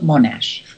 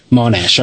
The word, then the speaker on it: Monash: You're getting close, but I'd call it Monash. – Monash, okay.